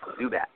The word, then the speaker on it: Zubac: The Hawks receive Jeremy Lin from the Nets and Avdija Zubac from the Lakers, as well as, yes, I think it's pronounced Zubats. I don't know how, but I've heard it pronounced that way several times, and it doesn't make any sense to me, but apparently that's how it's pronounced.